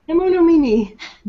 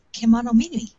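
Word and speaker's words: Kemonomimi: Kenonomimi. – There you go. – It's easy. You just read all the letters in the right order.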